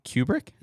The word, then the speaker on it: Kubrick: Directed by Stanley Kubrick? – Yeah. – Am I saying it right? – Yes, Kubrick? – You are.